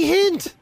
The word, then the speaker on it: Hynde: Chrissy Hynde.